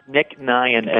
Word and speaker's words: Nick Nyhan: Nick Nyhan, N-Y-H-A-N.